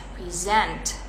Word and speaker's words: present: Nah tekanannya di sent ya, present. I present to you.